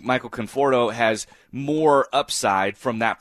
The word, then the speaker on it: Conforto: Michael Conforto, did I just butcher that name, Conforto? – You got it.